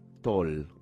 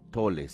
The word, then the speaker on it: Tolle: Eckhart Toll, o Tolle si uno pronuncia en alemán, Eckhart Toll justamente fue un eh, niño golpeado violenta y periódicamente por su padre alcohólico.